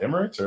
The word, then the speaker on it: Emirates: But when I went to look at flights out of Chicago myself, there was directs from Chicago to Dubai on Emirates. So is it Emirates or Emiratis? – Emirates, yeah.